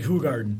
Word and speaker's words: Hoegaarden: It's actually Hoegaarden. – Hoegaarden? – Yeah, okay. – How's it spelled? – It's spelled one, but if you look at the label, the phonetics on the label are W-H-O.